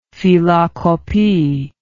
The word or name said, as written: phylakopi